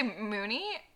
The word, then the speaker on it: Mooney: Moody. – Mooney. Moon. – Oh, Mooney. Mooney. See, there you go. See, I even forgot how to pronounce Mooney.